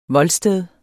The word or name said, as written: voldsted